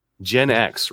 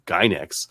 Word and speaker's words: Gen X: So I've been pronouncing these like Gynex, but Gen X, yeah, that sounds way better.